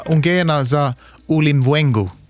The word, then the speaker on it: Ungena Za Ulimwengu: Jag kan försöka. Ungena za Ulimwengu. Lät det bra? – Det lät jättebra.